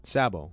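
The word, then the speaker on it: Tsabo: Sabo. – Sabo. Silent T or silent S? – Silent T. Sabo. Sabo.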